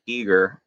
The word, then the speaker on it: Giger: And it really filled in all kinds of stuff before H.R. Giger got involved, or Geiger, or how you're supposed to say his name. – I always forget I think it's Giger. Technically, but anyway, it's a great documentary if you're a fan of the Alien series.